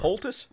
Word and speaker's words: poultice: So, a POTUS, what's that used for? – A poultice? – Poultice. – A poultice? – Yes, thank you for letting me pronounce that correctly.